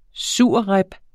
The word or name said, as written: surrib